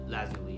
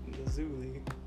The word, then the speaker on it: Lazuli: All I know is that I'm basically Lapis Lazuli. Like, and then that's the Zulu. – Lazuli is actually how to pronounce it. – Lazuli.